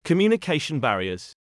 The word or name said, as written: communication barriers